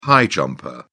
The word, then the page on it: high jumper: British English